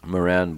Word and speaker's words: Moeran: Moran, born in 1895, died in 1950. Sorry, 1894, died in 1950. His last name is spelled Moeran.